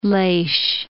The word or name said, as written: Leix